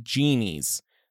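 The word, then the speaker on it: Gen Ys: Sam also said Gen Y's, but pronounce it genies. So the Y would be like an E.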